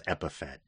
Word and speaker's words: epithet: Then again, a couple episodes back, I mispronounced epithet as epithet, like Boba Fett.